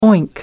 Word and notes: oink: American English

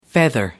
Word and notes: feather: American English

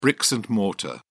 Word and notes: bricks and mortar: British English

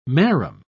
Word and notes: marram: British English

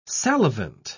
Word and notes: salivant: British English

